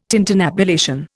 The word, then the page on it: tintinnabulation: Indian